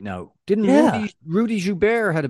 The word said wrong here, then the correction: Gobert: That's one way of pronouncing it, but... – Rudy Gobert? – Yeah, there you go.